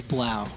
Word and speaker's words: Blau: B-L-A-U?